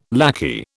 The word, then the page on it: lackey: British English